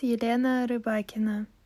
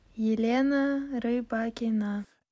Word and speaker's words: Elena Rybakina: How do we say that name again? – Hi, my name is Elena Rybakina. Elena Rybakina. – Elena Rybakina. So there you go. That's straight off the WTA website.